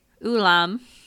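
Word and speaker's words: Ulim: How do I say his name? – Ulam.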